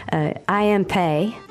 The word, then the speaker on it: Pei: C'est que le chef-d'œuvre de, j'essaie de bien le prononcer, Yao Ming Bei. – I am Pei. – Ah, c'est comme ça qu'on le dit ? – En anglais, oui.